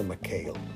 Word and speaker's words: McHale: No sé si lo dije bien, pero es La Marina de McHale. Eh, lo pasaban en el canal 3 los sábados en la mañana.